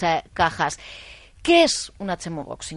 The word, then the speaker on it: Chemobox: Lo primero, ¿cómo pronunciamos correctamente el término? – Chemobox. – Tal cual. Como lo conocemos todos es Chemobox. – Chemobox, que es como se escribe.